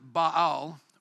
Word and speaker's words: Baal: I will destroy every remnant of Baal worship in this place. And some people pronounce it Baal. Baal is another way of pronouncing that word.